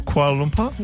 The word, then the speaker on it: Kuala Lumpur: Is it Sarah, is it Kuala Lumpur? – Yeah, you said it right. – Kuala Lumpur? – Yep.